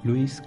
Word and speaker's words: Lewis: Estamos hablando de Alicia en el País de las Maravillas, el libro de Luis Carroll, para haberlo pronunciado bien. – Eh, Luis, como. Luis el trompetista. Como el trompetista, claro, exactamente.